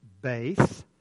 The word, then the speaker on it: Beth: Then after verse 8, in my Bible, and perhaps in yours as well, there is the little word, looks like Beth. It's actually pronounced Beth.